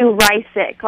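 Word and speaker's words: Reissig: Well, it's actually Rysick, like Rysick makes you sick. – I see, Rysick. Okay, thank you. – It's not how it's spelled at all, so it's really hard to say.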